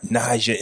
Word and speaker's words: Naja: So ladies and gentlemen, joining me on the block this afternoon, I got Naja in the building.